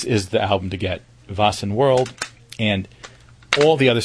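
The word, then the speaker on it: Väsen: Vassen, V-A-S-E-N, and the album is World, W-H-I-R-L-E-D. This is an incredible album. It's pronounced Vassen.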